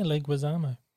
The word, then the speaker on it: Leguizamo: Is that how it's pronounced? – Yeah, Leguizamo. – Oh, I thought it was Leguizamo. – Leguizamo, no, it's Leguizamo.